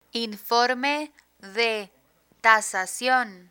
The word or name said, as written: informe de tasasion